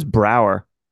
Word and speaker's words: Braugher: You know how to say his last name? – I thought it was Brower. – That's right. You're right. So Andre Brower passed away.